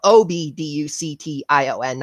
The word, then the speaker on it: obduction: Uh, uh, it's and it's that's spelled O B D U C T I O N, not A B,